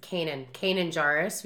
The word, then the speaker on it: Kanan Jarrus: Like, and also just to see the crossovers, like, the beginning scene, we see, I always say his name wrong, it's Kanan Jarrus.